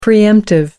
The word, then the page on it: pre-emptive: American English